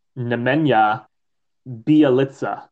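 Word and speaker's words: Nemanja Bjelica: Um, and I looked up how to pronounce it while you were talking, and uh, it's Nemenya Bialitsa. – There you go. I knew it was weird, but it's not spelled like that. It's spelled B J E L I C A.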